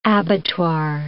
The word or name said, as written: abattoir